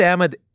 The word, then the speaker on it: Ahmed: So many Arabic speakers tell me I'm saying my own name wrong. But because Ahmed is an Arabic origin name, there are people who think the Arabic pronunciation is the only correct pronunciation.